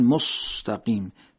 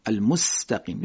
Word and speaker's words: المستقیم: مثلا در مستقیم اهدنا الصراط المستقیم، بعضیا میگن مستقیم، سین مستقیم رو شبیه صاد میگن. خب این صحیح نیستش. سین باید حالت طبیعی که بین فارسی و عربی یه جور ادا میشه همونطوری تلفظش بکنیم، المستقیم.